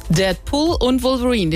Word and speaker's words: Deadpool und Wolverine: Deadpool und Wolverine, den Titel habe ich richtig ausgesprochen.